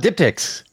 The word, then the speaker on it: diptychs: Are you talking about diptychs? – Yes, and I think they're cool.